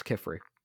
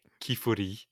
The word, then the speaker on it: Qifrey: I think it's Kifri. No, it's Kifri. In in Japanese, it's pronounced Kifuri.